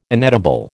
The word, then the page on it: inedible: American English